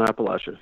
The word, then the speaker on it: Appalachia: Is that how you say it? Appalachia? – Yep. Hunt Appalachia. Yep. – That's it.